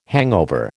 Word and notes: hangover: American English

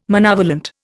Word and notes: monovalent: Indian

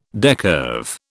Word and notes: decurve: British English

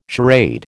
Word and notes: charade: American English

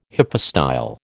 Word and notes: hypostyle: American English

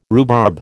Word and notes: rhubarb: American English

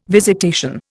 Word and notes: visitation: Indian

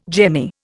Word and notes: jemmy: Indian

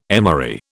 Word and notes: emery: British English

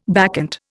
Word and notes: bacchant: Indian

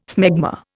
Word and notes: smegma: Indian

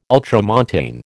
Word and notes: ultramontane: American English

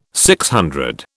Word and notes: DC: British English

